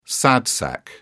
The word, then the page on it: sad sack: British English